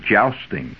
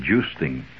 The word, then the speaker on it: jousting: Now most people call it jousting. It's actually joosting. And, yes, it's a correct, more accepted English pronunciation. And after all, joosting was an English game, so you better take their pronunciation.